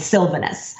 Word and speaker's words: Sylvanus: But I will say that the way I pronounce it is Saint Sylvanus. – Okay. – So I put the emphasis on that very first syllable.